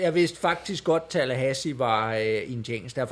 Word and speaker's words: Tallahassee: Og Tallahassee, Hassis, Tallahassee, sådan udtaler man det,